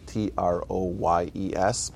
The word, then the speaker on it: Troyes: We don't know, but he was born in uh, in Troyes, which is spelled T R O Y E S, but somehow the French pronounce it Troyes.